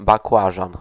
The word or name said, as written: Aubergine